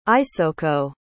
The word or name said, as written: isoko